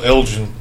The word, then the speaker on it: Elgin: Yes, Michael Elgin. – It's a hard G.